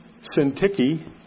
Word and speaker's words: Syntyche: I urge Euodia, and I urge Syntyche, and that is how you say it, to agree in the Lord.